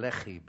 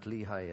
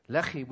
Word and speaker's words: Lehi: Then God opened up the hollow place in Lehi, by the way. That's how it's pronounced. Not Lehi, as in Lehi, Pennsylvania. Bix, I'm not picky on you. Lehi, which means the jawbone.